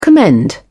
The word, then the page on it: commend: British English